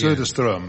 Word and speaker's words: Söderström: Then no, it's Söderström. – Söderström. – Elizabeth, just caught a sort of Swedish twang. – It's Söderström, that's right.